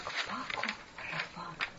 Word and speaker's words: Пако Рабан: до сих пор его имя произносится как Пако Рабан.